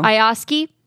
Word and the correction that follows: IATSE: No, I'm saying that wrong. How do you say it? – IATSE? – Yeah. They're about to strike. – IATSE. Oh, okay.